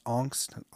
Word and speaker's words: Angst: I guess since it is German, it's pronounced Angst. I thought it was Angst. You know, like how we would say, you know, someone's angsty or whatever, but it's angst.